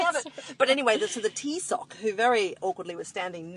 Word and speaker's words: Taoiseach: Now it's spelled Taoiseach. – Oh, okay. – And then, but it's actually pronounced Taoiseach, which is hilarious, like, to be sure. Tea in my sock.